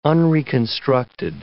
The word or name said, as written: unreconstructed